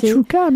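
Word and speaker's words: chakchouka: La fameuse chakouchka, moi je sais jamais le dire, c'est très bon, mais difficile à prononcer. – Chachouka, non? – Oui, oui. – C'est chachouka? – Oui, moi j'ai non, c'est ça. Chachouka.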